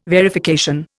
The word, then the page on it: verification: Indian